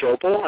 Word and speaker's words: Schoeppel: Kevin Schoeppel, and the book is The Bible, The Truth About Psychics and Spiritual Gifts.